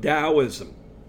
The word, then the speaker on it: Taoism: The next religion is Taoism, the ancient Ch- Chinese religion of Taoism the word dowel, and it's usually spelled T A O I S M, but it's pronounced like it's a D, D A O I S M.